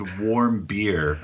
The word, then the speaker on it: Warmbier: Most people can't. It's warm beer. I know. Like, what's warm beer, right?